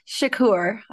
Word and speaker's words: Shakoour: I am doing great, and yes, I'm very excited about our guest, Nick Shakoour.